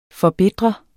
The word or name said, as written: forbitre